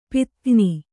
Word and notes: ಪದಗಾಣ್: padagāṇ

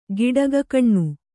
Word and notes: ಗಿಡಗಕಣ್ಣು: giḍagakaṇṇu

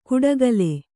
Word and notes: ಕುಡಗಲೆ: kuḍagale

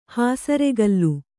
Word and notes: ಹಸುರುಕಲ್ಲು: hasuru kallu

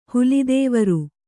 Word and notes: ಹೊಲದವರೆ: Kannada

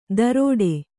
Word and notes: ದರೋಡೆ: Kannada

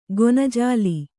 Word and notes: ಗೊನಜಾಲಿ: gona jāli

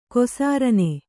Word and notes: ಕೊಸಾರನೆ: kannada; kosārane

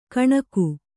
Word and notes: ಕಣಕು: kaṇaku

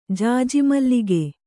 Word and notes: ಜಾಜಿಮಲ್ಲಿಗೆ: kannada

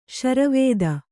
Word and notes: ಶರವೇದ: śara vēda

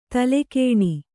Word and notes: ತಲೆಗಾಣ್: talegāṇ

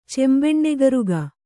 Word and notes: ಚೆಂಬೆಣ್ಣೆಗರುಗ: kannada

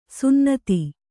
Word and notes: ಸುನ್ನತಿ: sunnate

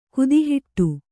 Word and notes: ಗೋತಿಹೊಡೆ: gōti hoḍe; Kannada

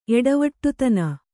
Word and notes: ಎಡವಟ್ಟುತನ: Kannada

